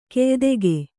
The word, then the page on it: ಕ್ಯಾದಿಗೆ: kyādige